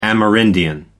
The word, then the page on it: amerindian: American English